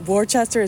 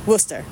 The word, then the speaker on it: Worcester: and it's so funny because it's spelled W-O-R-C-H-E-S-T-E-R, and some people call it Worcester, it's Worcester, you know what I mean?